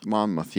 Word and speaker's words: Monmouth: Was it the Battle of Monmouth? I don't know how you say it. – Monmouth, yeah.